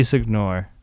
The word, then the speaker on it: Signore: How do you say his name? Andy Signore? – Andy Signore.